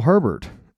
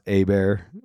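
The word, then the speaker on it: Hebert: And I got it on Fountain from Kyle Herbert. Just joking, Kyle. His name is actually pronounced Abear. It's spelled Herbert, but it's Kyle Abear.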